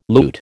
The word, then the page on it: loot: American English